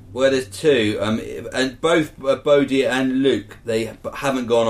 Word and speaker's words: Bodie: He's named Body, but I'm sure it's pronounced Bodie. – Bodie.